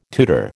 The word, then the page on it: tutor: American English